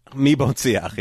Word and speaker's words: Mi Bon Siach: So how you say it? Mi Bon Siach? – Mi Bon Siach, yeah.